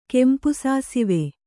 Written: ಕೆಂಪುಸಾಸಿವೆ

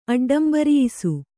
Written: ಅಡ್ಡಂಬರಿಯಿಸು

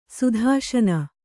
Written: ಸುಧಾಶನ

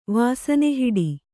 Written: ವಾಸನೆಹಿಡಿ

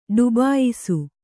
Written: ಡುಬಾಯಿಸು

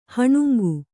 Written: ಹೀನಾಂಗ

